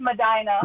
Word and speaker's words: Medina: If it's supposed to be pronounced Medina, I apologize. I never get those right. – It's pronounced Medina. – Ah! – Everyone else calls it Medina. – Yeah, like a normal person, but not a person from Ohio. Okay, yeah.